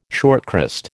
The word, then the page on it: shortcrust: American English